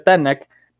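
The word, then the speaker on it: Tanaj: Es por esta razón que sí, que se pronuncia Tanak,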